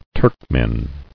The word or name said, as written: Turkoman